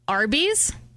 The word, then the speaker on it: Arby's: Arby's, you can pronounce it by saying the letter R and the letter B.